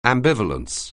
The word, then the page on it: ambivalence: anglais